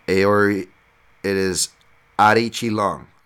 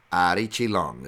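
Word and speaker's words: Aori Qileng: For your information, he says it is Ari Chi Long, not Quilang Aori, not Aori Quilang. It's Ari Chi Long is how it is pronounced.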